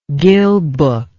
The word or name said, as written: gill book